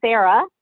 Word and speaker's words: Sarah: My name is Sarah, S-A-R-A-H, Rezondev, which is spelled R-E-Z-O-N-Z-E-W.